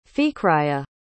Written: fikriyya

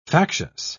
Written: factious

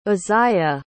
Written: uzziah

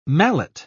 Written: mallet

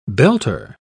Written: belter

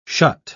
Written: shut

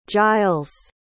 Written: jiles

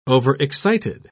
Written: overexcited

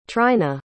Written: tryna

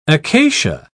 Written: acacia

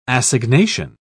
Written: assignation

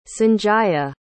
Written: sanjaya

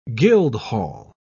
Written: guildhall

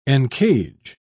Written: encage

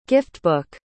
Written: giftbook

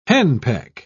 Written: henpeck

